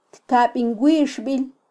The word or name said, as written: tkabinwixbil